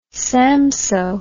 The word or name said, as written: Samsoe